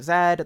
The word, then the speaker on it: Z: And if you want another sort of thing like that, I also co-write for the channel Sarah Zed. That's just the letter Z, but it's Canadian, so you say it like Zed.